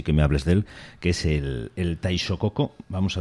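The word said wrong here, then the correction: taishōgoto: ¿Es el taishokoko, se dice? – Taishokoto. – Taishokoto. – Sí.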